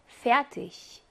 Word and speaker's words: fertig: 그 다음에 이기라고 하는 요 철자 조합으로 단어가 끝났을 경우에 여기에는 기가 히 발음을 냅니다. 즉, 이시에서처럼 복잠 체하와 똑같은 히 발음을 냅니다. 어떻게 읽으면 돼요? Fertig.